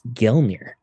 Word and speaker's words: Gelnir: It reads Gelnir, G-E-L-N-I-R. – Who read it out loud? Gelnir? Look right there, the spear. It is the river language, oh. Gelnir.